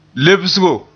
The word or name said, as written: lebsgo